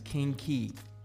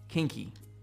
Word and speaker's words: Qingqi: Her name is Kinky. Kinky. It's spelled Q-I-N-G-Q-I. Kinky.